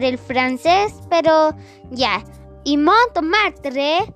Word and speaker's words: Montmartre: Bueno, ahora vamos a otro palacio al Palacio de Montramarte. Si es que así se pronuncia. Así que vayamos. Antes de decirles datos de Montmartre, que es así como se pronuncia, es la primera corrección que voy a hacer porque voy a hacer tres correcciones.